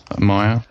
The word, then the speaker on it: Mayor: I think it's Meyer. – M-A-Y-O-R. – Yeah, as in Mayor. Meyer.